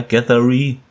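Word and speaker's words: Gereghty: Äh, William Gathery, spreche ich das denn richtig aus ungefähr? Gathery? Ja, hätte ich auch so ausgesprochen, ja.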